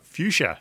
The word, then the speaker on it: fuchsia: Frushka? Uh, fuchsia.